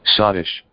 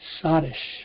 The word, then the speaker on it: sottish: They are skittish or Scottish. I'm not sure how to pronounce that. Let me see. – Sottish. – Sottish. Okay.